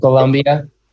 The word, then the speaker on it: Colombia: did i pronounce that closer this time colombia perfect, perfect.